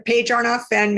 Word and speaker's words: Paige Arnof-Fenn: Yeah, Paige Arnof-Fenn, Mavens and Moguls.